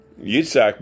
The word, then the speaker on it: Yitzhak: And so Yitzhak was, which is the actual Hebrew pronunciation of Isaac's name, was circumcised, and all of his children were circumcised, and his grandchildren, and so on and so forth.